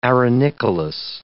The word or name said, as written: arenicolous